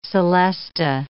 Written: celesta

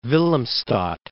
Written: Willemstad

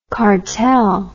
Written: kartell

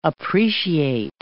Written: appreciate

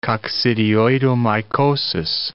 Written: coccidioidomycosis

